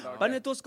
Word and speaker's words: Panetoz: Panetoz kommer in, det rimmar bättre. Panetoz kommer in, det rimmar bättre. Okej, en sista gång, hur uttalar man det? 1, 2, 3.